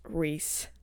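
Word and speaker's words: Rhys: Maas fans, like, flock to the group chat and be like, it's pronounced Reese.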